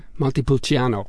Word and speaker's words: Montepulciano: Montalcino. Is that right? Did I say that right? – Montepulciano.